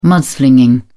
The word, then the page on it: mudslinging: British English